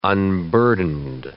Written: unburdened

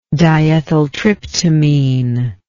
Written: diethyltryptamine

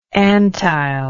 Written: Antheil

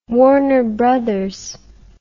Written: Warner Brothers

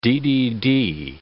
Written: DDD

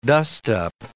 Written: dust-up

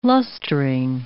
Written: lustring

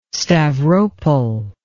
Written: Stavropol

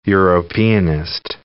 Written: Europeanist

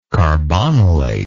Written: carbonylate

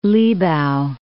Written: Libau

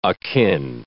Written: akin

